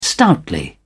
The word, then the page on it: stoutly: British English